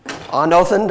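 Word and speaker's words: Anothen (ἄνωθεν): And I've told you that it's the Greek word where it says born again, that it's actually anothen. Familiar with that word? Did I get that right? Anothen, did I say it correctly? – That's good.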